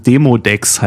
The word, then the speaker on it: Demodex: Ich habe es falsch ausgesprochen. Demodex heißt es.